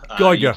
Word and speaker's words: Geiger: Have I got your surname right or have I butchered it? – You nailed it the first time, Geiger.